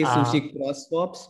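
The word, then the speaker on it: SushiXSwap: I'm not sure if I'm pronouncing correctly. Hopefully I am. But it's actually Sushi Cross Swaps, but yeah. – Sushi Cross Swap. Yeah, see, I knew I wasn't getting it right.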